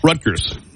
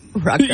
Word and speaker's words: Rutgers: More recently, Rutgers University faced backlash for canceling a graduation speaker over pro-Palestinian. – You hit a soft G instead of a hard G. Rutgers. – Rutgers.